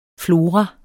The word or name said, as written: flora